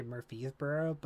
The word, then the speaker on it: Murfreesboro: The easy way to remember Middle Tennessee State is because just, I always want to say Murfreesboro, but I purposely make that R pronounced in my head.